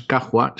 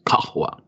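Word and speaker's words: cajua (قهوة): Es un tipo de K que se pronuncia con el fondo de la garganta. O sea, no es cajua, sino que es cajua.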